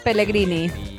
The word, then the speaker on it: Pellegrini: Pellegrini.